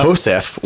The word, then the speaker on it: Josef: The man identified as Philip, I want to say Joseph, but I want to say Joseph. – Jose? – But it's spelled J-O-S-E-F, so I'm pretty sure it's Philippe Josef.